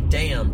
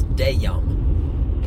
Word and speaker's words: damn: When you say damn to describe it, you got to break it up into two syllables. It's not damn, it's dayum.